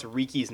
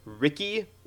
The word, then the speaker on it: Riki: Because I swear, when they first started out, like in the first episode, they were pronouncing it like Riki, like, you know, like R-I-C-K-Y. But then, like, by about episode 2, they got where everyone was pronouncing it Riki properly.